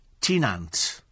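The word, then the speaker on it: Tŷ Nant: But apparently, Thai is pronounced the same as tea. Tynant.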